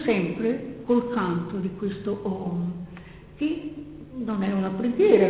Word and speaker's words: Om: La sillaba Om, che di fatto è fatta di tre elementi, A, U, M, ma si pronuncia Om, che viene cantata moltissimo con un tono prolungato, è interessantissimo sentirla.